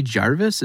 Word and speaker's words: Gervais: And my favorite one was Ricky Gervais. Is that how you say his name? – Yeah.